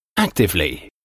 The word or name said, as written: actively